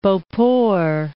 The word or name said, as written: Beauport